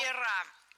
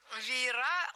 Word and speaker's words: веера: Андрей Олегович, не знаю, будет тебе интересно, но, возможно, тебе будет интересно узнать, где правильно ставить ударение в слове «веер» множественное число. Как ты думаешь? – Ну, на последнюю... Ой, бог, сейчас все будет плохо. Нет, угадал? – Веера. Веера, лорнеты, трости.